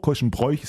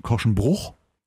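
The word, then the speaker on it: Korschenbroich: Was sagst du, Korschenbruch, Korschenbräuch? Ist das eine alte Philosophiefrage, ne? Ich glaube, man sagt offiziell, äh, offizielle Aussprache ist Korschen, Korschenbruch.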